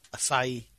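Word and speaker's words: açaí: Anyway, there's a juice smoothie place I like, and they serve, how do you say that? Acai? Acai? – Yeah, acai.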